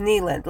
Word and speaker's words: Kneeland: So in 1839, Abner Kneeland, his name is Kneeland, like a knee, K-N-E-E, Kneeland,